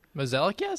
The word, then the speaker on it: Mozeliak: Is it John Mozeliak? Is that how you say it? It's spelled a little differently than you say it. – Mozeliak, yeah, sounds about right.